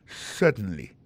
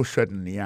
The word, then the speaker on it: certainly: certainly alors en parlant de accent regardez bien pour un mot certainly accent tombé sur premier syllabe là yes certainly témoin point pour encore yes certainly comment accent tombé sous premier syllabe là.